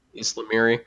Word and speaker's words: ysalamiri: Is it Ysalamar? Ysalamiri.